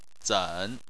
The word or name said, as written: zen3